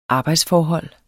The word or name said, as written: arbejdsforhold